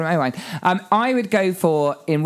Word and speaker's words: Owain: Is that Owen or Owain? – Owain, Welsh. – Okay. – Would some people pronounce that Owen? – Not if you're Welsh, no. – Okay. – If you're going to anglicise and Americanise it would be Owen. But it's Owain.